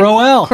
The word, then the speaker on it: Crowell: Well, changing gears, New York Jets running back Isaiah Crowell ran... Did I say it right? – Crowell. The crow's going to fly.